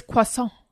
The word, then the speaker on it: croissant: To say that you don't pronounce the T. So right. – I am bastardized. It's croissant.